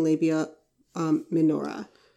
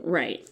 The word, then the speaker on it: labia minora: labia minora. Did I say that right? – Right.